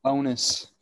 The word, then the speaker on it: Sabonis: Oh boy, Domantas Sabonis. Did I say that right? – Yeah. – Yeah. Fucking nailed it. Let's go. Sabonis.